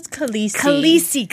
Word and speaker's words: Khaleesi: Her title is Khaleesi.